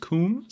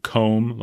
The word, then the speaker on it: Coom: I've been told on our YouTube channel that it should be pronounced Coom. So that's what I'm going to do, as opposed to like comb, like what you brush your hair with.